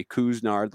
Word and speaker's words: Couisnard: And that is how you pronounce it, everybody. Cousinard, like K-O-O-S, and then Nard.